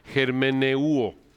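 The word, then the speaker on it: germeneuo (ἑρμηνεύω): La palabra hermenéutica, como lo pueden ver aquí en la transparencia, viene de la palabra griega, se pronuncia germeneuo. Germeneuo.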